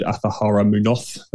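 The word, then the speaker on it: Azahara Muñoz: And I played with the Spaniard, the wonderful Spaniard, Athahara Munoz. There we go. Put some accent on there.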